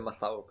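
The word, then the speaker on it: Matthau: Decían en, los premios y eh, en, las entrevistas que vi, como para cachar cómo se pronunciaba, lo decían siempre Mazau, pero está lo mismo en realidad.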